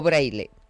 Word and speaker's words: Braille: ¿Vosotros cómo lo decís? ¿Braille o Braille? – Nosotros decimos Braille, que es como se debe pronunciar en español, porque si no, pronunciarlo en francés sería un poquito más complicado. Pero lo bueno y lo ortodoxo es decir Braille.